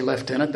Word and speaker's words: Lieutenant: I say Lieutenant, that's the British pronunciation.